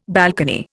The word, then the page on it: balcony: Indian